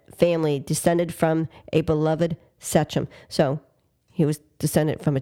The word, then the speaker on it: sachem: Sachem. – Oh, I'm sorry. My mouth was in there. Do it again. – Sachem. – Sachem? – Sachem. – Sachem? – Sachem. S-A-C-H-E-M, but the pronunciation is S-A-Y-C-H-M.